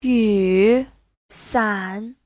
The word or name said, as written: yu3san3